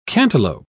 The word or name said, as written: cantaloupe